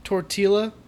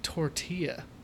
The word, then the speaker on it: tortilla: Devil L is with a Y pronunciation in Spanish. – It's fucking tortilla. – When you say have a double L, it's a yeah sound.